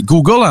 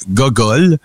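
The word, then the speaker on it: googol: Il y en a qui disent que c'est une affaire, il y en a qui disent que c'en est un autre, mais Google, en fait, vient du mot, en anglais, ça se prononce Google, et en français, ça se prononce Gogol.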